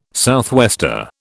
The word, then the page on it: southwester: British English